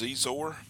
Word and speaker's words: Xizor: Next is Prince Xizor. It's spelled X-I-Z-O-R, so that's why Z and then Zor.